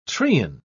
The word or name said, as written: treen